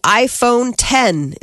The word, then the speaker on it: iPhone X: Apple is unveiling the iPhone 10. It's the iPhone X, but it's pronounced iPhone 10.